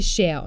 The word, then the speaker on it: Cher: Und ausgesprochen wird sie Cher und nicht Cher wie der Stuhl, ne?